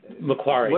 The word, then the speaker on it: Macquarie: So in the intro, I mentioned Macquarie Securities, but I mispronounced that. It's Macquarie.